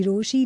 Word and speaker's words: Rochie: I am your ब्रोशी, the name is मिर्ची रोशी, नाम की स्पेलिंग ROCHIE.